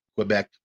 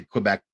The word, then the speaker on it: Quebec: That's how they say it. Quebec. I say Quebec.